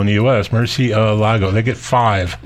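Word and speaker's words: Murciélago: No, I think it's like Murcielago.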